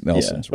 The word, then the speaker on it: Neilson's: Is it Neilson's or Nelson's? I've lived here all of my life. it's it's spelled Neilson's, but I think it's Nelson's. Yeah, but right? nobody actually does that.